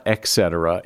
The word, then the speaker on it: et cetera: You often hear people say et cetera, but it's not et cetera. It is et cetera.